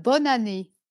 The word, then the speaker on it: bonne année: In French, bonne année? – Yeah, not bad, bonne année.